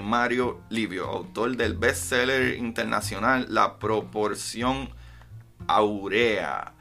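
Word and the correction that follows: Áurea: Uy, Aurea, actually. Eh, tiene acento en la La Proporción Aurea, de Mario Livio.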